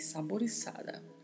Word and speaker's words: saborizada: Saporizada aqui pronuncia-se assim mesmo, com a letra Z tendo o som de S. Escreve-se saborizada com a letra Z, mas a pronúncia é com S, saborizada, saborizada.